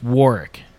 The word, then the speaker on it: Warwick: Yeah, it's Warwick Davis. – Warwick Davis. – Yeah. – So do not pronounce the second W in Warwick Davis.